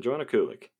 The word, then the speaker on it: Joanna Kulig: Kulig? – Yeah, an easy one. Joanna Kulig.